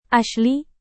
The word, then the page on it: asli: indonesian